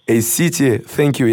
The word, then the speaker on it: A-City, thank you: Итак, дорогие друзья, для вас сейчас звучит трек от MC Дотмана A-City, thank you. Я все правильно сказал? – Да.